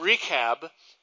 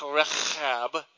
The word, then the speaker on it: Rechab: The son of Rechab, and uh, actually that would be Rechab, you know, if you wanted to pronounce it right,